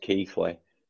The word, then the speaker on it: Keighley: It's pronounced Keighley. Well, because there's a town about 10 miles away from us, and it's called Keighley, and it's spelled exactly the same way as his last name.